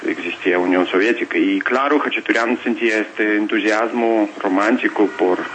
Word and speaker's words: Khachaturian: Khachaturian en ruso, aquí cambian acento en español, como ustedes no ponen acento en la última sílaba cuando la palabra termina en N, dicen Khachaturian, pero realmente en ruso dicen Khachaturian.